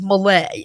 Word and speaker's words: Malay: So anyway, the correct pronunciation, if you're wondering, is Malay, as in Malaysia.